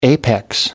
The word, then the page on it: apex: American English